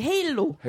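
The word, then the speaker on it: Halo: 저는 헤일로.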